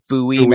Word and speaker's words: Bowie: Did you know that there is a town in Maryland that's spelled the same way? It's Bowie, Maryland. – You know that? – So not everything that's spelled the same is pronounced the same.